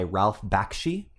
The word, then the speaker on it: Bakshi: The first one is Wizards from 1977, and it was directed and animated by Ralph Bakshi.